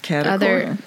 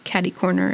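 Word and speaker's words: catty-corner: It's cata corner. – There are other pronunciations of it, but yes, caddy corner is one. Kitty corner is another.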